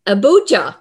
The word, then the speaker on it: Abuja: which is an integrated health center in Abuja.